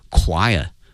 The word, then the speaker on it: choir: 这个演出呢它的一个意义呢在于 featuring 哈，它呢是庆祝 the Shanghai Disabled People Choir 啊，那么说上海残疾人合唱团，这个合唱团它的拼写是 C-H-O-I-R，它的发音是 choir 哈，它不是按那个 C-H 啊传统的一个发音的方式来念啊，它是发 k 这个音哈 choir。